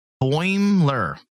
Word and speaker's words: Boimler: No, Boimler. There's not three syllables. Two.